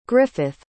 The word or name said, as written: griffyth